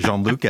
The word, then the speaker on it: Jean-Luc: I think Patrick Stewart really helped a lot to boost up pronunciation of Jean-Luc as an English name or as a name that could be pronounced by people who speak English.